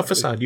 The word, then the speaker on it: facade: No, facade, you had it.